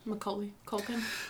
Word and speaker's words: Macaulay Culkin: Macaulay Culkin. Am I saying that right? – Macaulay Culkin.